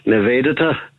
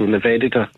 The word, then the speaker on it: Nivedita: I asked Lord Rana to pronounce it for me, and he pronounced it more like Nivedita. You know, and then I asked a Hindu nun in Calcutta to pronounce it for me, and it was Nivedita. So it's probably that's the more correct one, Nive. So the emphasis on the E is an A, Nive, and then the latter part Dita, but it tends to be a kind of declining Dita, so Nivedita.